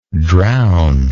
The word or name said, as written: drowner